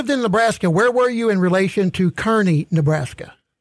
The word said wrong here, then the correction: Kearney: Yeah, come on. We got to pronounce that right. That's Kearney.